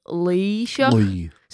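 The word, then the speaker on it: Laoighseach: The actual first syllable of that is really hard to get. So it's like a deeper kind of e sound, like Leisha.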